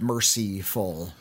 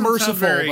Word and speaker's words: Mercyful: And it's I pronounce it that way because there's a Y in it. Mercyful. Not merciful, but Mercyful Fate.